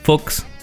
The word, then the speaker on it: Fuchs: Ariel Dan Fuchs. Se escribe como Fax, pero no es.